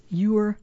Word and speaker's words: Eure: And that's spelled E-U-R-E. My apologies if I've been mispronouncing it this whole time. Yes, Ewer, North Carolina, 27935.